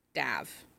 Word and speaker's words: Dev: Because to be honest, to hear the two of them pronounce his name, it's Dav. Like not... D-E-V, D-A-V is how, like, I had to go in and physically look at my book to make sure the name wasn't Dave.